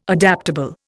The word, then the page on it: adaptable: Indian